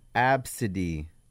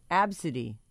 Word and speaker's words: Abcde: It's pronounced Absidy. – Absidy. Absidy. – No, it's pronounced A, B, C, D, E. It's the alphabet. We're all familiar. – It's like Rhapsody, but it's Absidy.